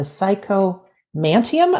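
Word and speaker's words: psychomanteum: And number 7, the psychomantium.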